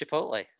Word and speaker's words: Chipotle: And it's pronounced Chipotle.